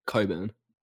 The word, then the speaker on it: Cockburn: And as we were driving down, I realized we were in Coburn. But Coburn is spelled Cockburn. – Wait, how is it pronounced? – Coburn.